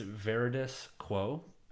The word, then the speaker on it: Veridis Quo: Yeah, it's Veridis Quo is the actual name, but it's meant to be pronounced like very disco.